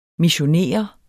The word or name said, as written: missionere